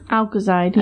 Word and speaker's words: alkoxide: Alkoxide. It's right there. A-L-K-O. – Alkoxide, yeah.